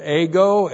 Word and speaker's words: ego: And then the personal pronoun ego.